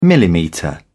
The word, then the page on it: millimetre: British English